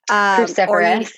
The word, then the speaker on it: cruciferous: Another thing I kept seeing was no spinach, no, I can never say it, carnivorous. Is that how you say it? Vegetables? – Cruciferous.